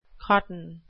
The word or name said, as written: cotton